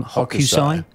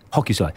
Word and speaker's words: Hokusai: Is the chap who painted the Great Wave, I can never pronounce his name, Hokusai? – Hokusai. – Yeah, Hokusai.